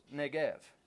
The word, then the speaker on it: Negev: But even when we were in Israel, all the signs were spelled with a V, not with a B. So it's Negev. He heads with his family and everyone that he has down into the Negev.